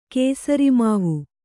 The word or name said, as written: ಕೇಸರಿಮಾವು